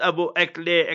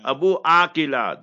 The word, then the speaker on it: Abu Akleh: And not Abu Akleh. Akleh, that is all Western media propaganda. Abu Akila, that's the proper Arabic pronunciation.